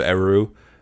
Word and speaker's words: Eru: If you want to hear the pronunciation of Eru, like for real, just listen to Marilyn in this follow-up interview. She's like, Eru.